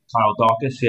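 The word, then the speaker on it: Daukaus: Now, the next fight, Kyle Dukas. Did I say that right? – Kyle Dukas, yeah.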